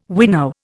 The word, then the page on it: winnow: Indian